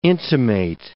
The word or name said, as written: intimate